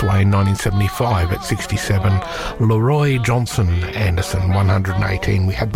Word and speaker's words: Leroy: Leroy Anderson, and he does insist that it is pronounced Leroy, not Leroy. Even though I've always said Leroy Anderson when I've referred to him as a performer, but no, it's apparently Leroy Anderson.